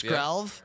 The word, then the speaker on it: Skrelv: How do you say this card? – Help us Skrelv. – Out. – I think Skrelv. – It's Skrelv.